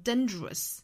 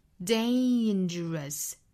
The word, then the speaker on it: dangerous: dangerous 那我听到过不止一个同学把它读成 dangerous 好，那这是非常错误的也是非常不好听的一定要注意是一个双元音 a dangerous